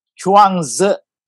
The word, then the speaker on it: Chuang Tzu: How do you pronounce it? – Chuang Tzu. – Chuang Tzu. – Yes.